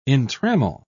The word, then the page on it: entrammel: British English